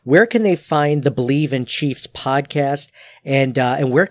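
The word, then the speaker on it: Bleav: It's spelled a little interestingly. It's B-L-E-A-V. So it's Believe, in pronounced Believe, Believe in Chiefs.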